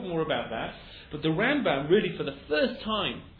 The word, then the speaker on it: Rambam: The Rambam, Rabbeinu Moshe ben Maimon, usually is the emphasis on the beginning of the word.